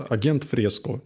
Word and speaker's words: Agent Fresco: Есть, по-моему, исландская группа Agent Fresco, называется, как агент пишется, агент Fresco, как Жак Фреско, только агент.